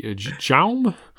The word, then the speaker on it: Jaume: Well, it's J-A-U-M-E. Jaume? – Jaume. – Okay.